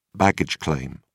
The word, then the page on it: baggage claim: British English